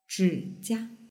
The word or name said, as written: zhijia